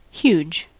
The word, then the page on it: huge: American English